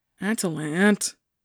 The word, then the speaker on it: Atalant: Atalant, Emin said with a slight emphasis on the last syllable.